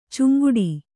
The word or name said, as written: ಚಂಗಾಟ